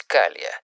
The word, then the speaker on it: Cala: Слово «кала» — испанского происхождения, а потому читается и, соответственно, пишется по правилам испанского языка, то есть «калия». Здесь и далее примечание переводчика.